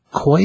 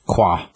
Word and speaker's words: Quoi: But yeah, this is a Koi, is that how you say it? – Qua. – Qua. So Qua's his name. – Q-U-O-I.